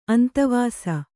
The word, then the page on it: ಅಂತವಾಸ: antavāsa